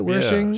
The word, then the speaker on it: Wershing: You've got Anne Wershing. Is that how you pronounce it? Wershing.